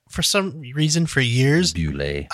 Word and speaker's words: Boulet: I called this the Belway brothers, and well, oh, I don't I know why. That. I just completely. it doesn't it's not spelled like that at all. No. but for some reason, my mind flipped the L and the W, and for years I called it the Bellway Brothers, but it's the Bewlay Brothers.